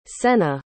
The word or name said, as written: sena